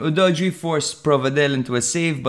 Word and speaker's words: Udogie: Now, in the 26th minute, Udoji, the commentator calls him Udoji. And by Jerami, we really need to work on our pronunciation. – Nah, maybe they need to work on their pronunciation.